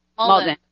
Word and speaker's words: Maldon: Maldon.